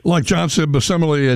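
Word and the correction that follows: Bamisile: at VCU, Bamisil, excuse me, I'm pronouncing it wrong, Bamisil. Give me a minute. Let me say it 10 times. I got it right.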